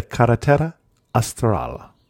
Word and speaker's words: Carretera Austral: When we talk about Chile, this time we're talking about, and I'm going to try and pronounce it, the Carretera Austral, the Southern Perfect. – Highway. – That was good pronunciation.